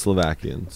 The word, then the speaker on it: Slovakia: He doesn't identify as Slovakian, sorry. – But I'm curious. – How do you say it? How do you say it? – Slovakia. – Slovakia. Yeah. – Slovakia.